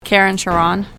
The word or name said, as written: Keren Sharon